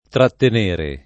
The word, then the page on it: trattenere: lingua italiana